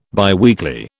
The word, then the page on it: biweekly: British English